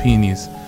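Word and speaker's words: Pini: I'm thinking of ElfQuest, the peonies. That sounds really wrong when I say it like that, but it's P-I-N-I, that's them.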